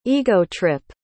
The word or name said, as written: ego-trip